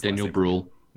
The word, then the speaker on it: Daniel Brühl: Daniel, you're gonna have to pronounce Daniel Brühl. – Yeah, that's right.